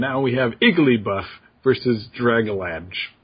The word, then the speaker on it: Dragalge: So the two are Igglybuff and Dragalig. Nope, sorry. Dragalg. Dragalg? I don't know how to pronounce that. Anyway. – So I'll complete it as Dragalge.